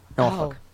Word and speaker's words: Norfolk: Oh, there were some out of town people that used to read ads on commercials on Norfolk radio stations, and they would pronounce it really, really Ooh. – Incorrect. Yeah. Nor. F-U-C-K.